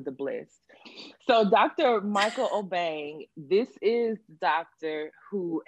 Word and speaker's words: Obeng: So, Barbara, can you please tell me how to correctly pronounce this man's name? – So Dr. Michael Obeng. – Obeng.